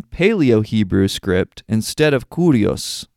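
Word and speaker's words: Curios (Κύριος): It seems that even though the name was written in Paleo Hebrew, it was intended to be pronounced as Curios when read aloud.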